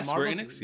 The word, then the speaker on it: Square Enix: And even moving on to what was released from the Marvel world at E3, It's this Square Enix. I think I'm saying that right. – Yeah, Square Enix.